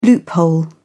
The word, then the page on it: loophole: British English